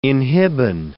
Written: inhibin

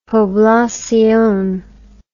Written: poblacion

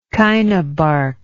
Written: chinabark

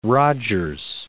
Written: Rogers

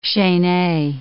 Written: chaine

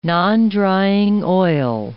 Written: nondrying oil

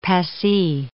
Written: Passy

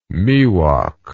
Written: miwok

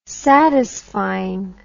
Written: satisfying

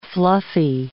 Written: fluffy

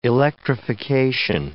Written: electrification

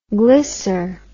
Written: glycer

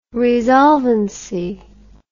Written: resolvancy

